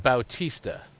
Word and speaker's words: Bautista: What's Baptista? What's he done since the All-Star break? And all this talk about him being the MVP. – There's no T in Bautista.